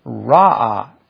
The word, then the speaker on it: Ra'ah: Now, the word shepherd in the is pronounced something like, not exactly like, Ra'ah, R-A-A-H. And I'm sure I'm not getting it right.